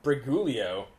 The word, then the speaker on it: Briguglio: It's the second G is silent. – Briguglio.